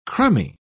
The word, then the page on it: crumby: British English